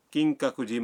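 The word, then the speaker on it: کنگ کا کجی (金閣寺): مثلاً آپ کیوتو کے کنگ کا کجی مندر جانا چاہتے ہیں.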